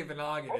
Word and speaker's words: Vanagon: Vanagon, did I say that wrong? – No, I always say Vanagon.